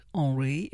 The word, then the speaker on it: Henri: And if you want to look him up, it's spelt Henri in the French way, H-E-N-R-I, and then Pipeman is P-E-I-P-M-A-N.